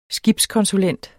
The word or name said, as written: skibskonsulent